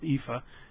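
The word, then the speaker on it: EAFE: that's pronounced EFA,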